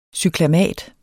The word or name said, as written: cyklamat